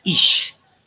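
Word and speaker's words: ish (איש): de la palabra hebrea ish, que se escribe Aleph, Yod, Shin, Ish.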